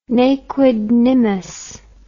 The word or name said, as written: ne quid nimis